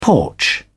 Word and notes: porch: British English

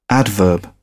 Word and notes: adverb: British English

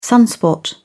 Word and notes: sunspot: British English

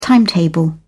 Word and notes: timetable: British English

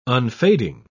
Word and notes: unfading: British English

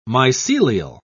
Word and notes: mycelial: British English

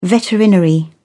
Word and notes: veterinary: British English

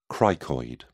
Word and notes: cricoid: British English

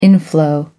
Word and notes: inflow: American English

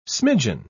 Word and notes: smidgin: British English

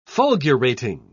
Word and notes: fulgurating: British English

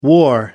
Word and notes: wore: American English

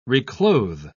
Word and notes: reclothe: British English

